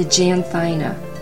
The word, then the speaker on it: Janthina: A really nice example to use of that would be the Janthina, J-A-N T-H-I-N-A, in case I'm pronouncing it incorrectly.